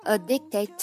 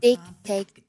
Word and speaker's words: addicted: You are the drug 的略读 That I'm addicted to That 和 am 要连读成 That I'm 和 addicted to 要连读成 I'm addicted to Addicted 的略读还有这个单词啊 Addicted 要注意那个 C K 的音不要把它发出来不是 addicted 不是这样的是 addicted 要是有一个停顿这个 k 要有一个象征性的发音但是你要不要把它发得很重